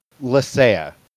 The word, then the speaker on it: Lecea: Lecea.